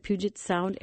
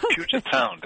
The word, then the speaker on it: Puget Sound: Oh, Susie, you said it perfectly. Puget Sound, indeed.